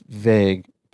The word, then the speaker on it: Vegh: Well, my next one is a guy named, I think it's pronounced Vague, but I'm not exactly sure. It's Christian Vegh. It's V E G H. Okay. Is that how you would pronounce that? I would say vague, yeah.